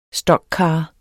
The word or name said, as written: stockcar